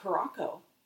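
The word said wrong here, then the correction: Curaçao: I thought it was Blue Caraco. – Curacao.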